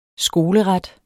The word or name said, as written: skoleret